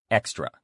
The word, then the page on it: extra: male